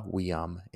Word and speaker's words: viam: Fata viam invenient. Fata, F-A-T-A, viam, spelled V-I-A-M. Remember, V's are pronounced as W's. And then invenient. I-N-V, right? I-N, invenio. V-E-N-I-E-N-T.